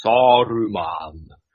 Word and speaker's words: Saruman: no, it's Saruman. Saruman.